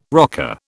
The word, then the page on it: rocker: British English